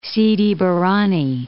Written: Sidi Barrani

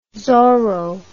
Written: zorro

